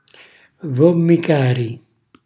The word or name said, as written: vommicari